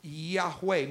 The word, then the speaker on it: Yahweh: If you put the sounds to it, which are missing there because the Jews don't put them there, then it would be pronounced Yahweh.